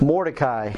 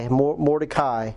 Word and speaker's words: Mordecai: Mordecai. So I don't know if you can spell it. M-O-R... More D-E-C-A-I. Mordecai. M-O-R-D-E-C-A-I.